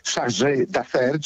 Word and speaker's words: chargé d'affaires: No, nazwa, która pochodzi z języka francuskiego i którą się wymawia, przepraszam bardzo, chargé d'affaires, czyli osobę, której powierzono wykonywanie spraw za ambasadora.